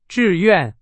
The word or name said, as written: 志愿